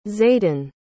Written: zaidin

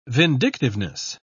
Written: vindictiveness

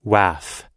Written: waff

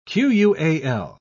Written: qual

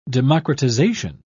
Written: democratization

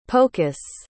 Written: pocus

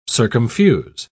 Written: circumfuse

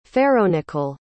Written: ferronickel

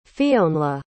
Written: Fionnlagh

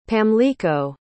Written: pamlico

